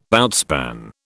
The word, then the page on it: outspan: British English